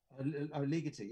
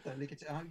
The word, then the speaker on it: Ligeti: A bit of Ligeti, is that how you pronounce it? – Ligeti, yeah. – Ligeti. – Yeah. But yes, it is in some ways it is, yes. – Ligeti, is that how you say it? – Ligeti, that's how I say it. Ligeti, yeah, I think so.